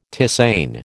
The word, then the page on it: tisane: American English